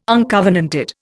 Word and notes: uncovenanted: Indian

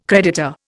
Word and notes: creditor: Indian

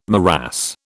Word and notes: morass: British English

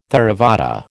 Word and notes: Theravada: American English

